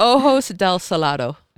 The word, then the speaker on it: Ojos del Salado: Is it Oso del Sado? – Yeah, close. – That's close. In South America. – Like Ojos del Salado.